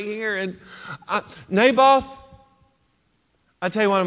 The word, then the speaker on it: Naboth: It's Naboth.